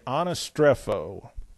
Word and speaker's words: anastrepho: the Greek word is anastrepho. Now, this doesn't mean a whole lot here. I just want to show you the kind of problem you can get into with this. A-N-A, anastrepho, S-T-R-E-P-H-O, anastrepho, looks like that in Greek.